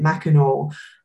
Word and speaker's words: Mackinac: it's called Mackinac. Some people pronounce it Mackinaw, and cars are actually banned on there.